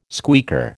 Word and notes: squeaker: American English

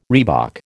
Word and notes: reebok: American English

